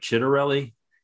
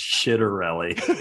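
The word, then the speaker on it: Ciattarelli: Uh, it looks like Chitterelli I was told it is actually pronounced Shitterelli.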